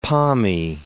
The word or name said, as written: Pommy